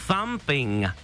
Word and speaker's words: Thumping: Jo, to th je toho, vyslovuje se to tak, že strčíš jazyk mezi zuby. Thumping.